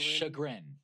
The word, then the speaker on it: chagrin: How do you say that? Chagrin? – Chagrin. – Chagrin, yeah. I always see it like written, but I never actually read it out loud.